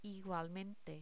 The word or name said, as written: igualmente